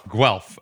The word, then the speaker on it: Guelph: You graduated from the University of Guelph, if I'm pronouncing that right. – Guelph. – Okay. – Guelph.